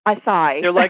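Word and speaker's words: açaí: And I never know how to pronounce this, but acai berry, is that how I say it? – Acai.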